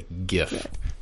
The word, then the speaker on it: GIF: the people who created it wanted it pronounced as GIF with a G sound. It's a GIF. Yes. Almost like the other word for present, GIF. – Just drop the T. – Yes. There you go. There you go. – Or if would have pinned J-I-F, right? – Do what?